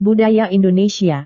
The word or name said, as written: budaya Indonesia